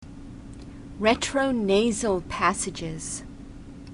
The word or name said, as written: retronasal passages